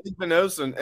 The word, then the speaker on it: Igbinosun: Is it Igbinosun is how we say his name? Right? – Igbinosun. And he even has OSU in his last name.